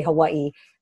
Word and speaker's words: Hawaii: No, well, so according to people who live on the mainland, we say it, the local people say it like somebody's punching us in the gut. So we say Hawaii. So it's Hawaii. Always like Hawaii.